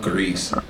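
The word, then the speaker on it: Gris: And one of them I definitely want to play is one called Greece. It's, well, I think it's pronounced Gree.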